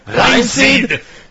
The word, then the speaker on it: Reinxeed: Reinseed. – R-E-I-N-X-E-E-D.